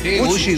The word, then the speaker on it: Gucci: Varias cadenas tipo Gucci, ¿se dice así? – Gucci, Gucci.